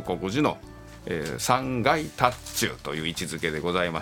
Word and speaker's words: 塔頭: これを禅宗では特に塔頭と読みます。塔の頭と書いて塔頭というんですけども、